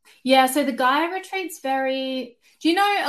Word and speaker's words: Gaia: Is it Gaia or Gia? – Gaia. – Gaia.